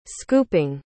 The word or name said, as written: scooping